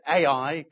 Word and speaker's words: Ai: And then a city called, I pronounce it AI, could pronounce it I, great name for a city, the city of Jericho and Ai.